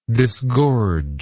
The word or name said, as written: disgorger